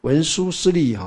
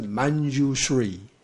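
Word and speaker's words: Mañjuśrī: 看义观，是时文殊师利法王子啊，文殊师利哈，世上这个北京话称为念成文殊师利，对不对啊？但是。事实上照梵音它是 Manjushri 啊，所以有时候就翻成曼殊室利，有没有啊？那为什么会这样翻呢？反而是曼殊室利反而就不会错，因为梵文是 Manjushri 是曼，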